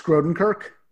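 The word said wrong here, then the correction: Grodénchik: Yes, is that how you pronounce it? – Max Grodencheck. – Grodencheck.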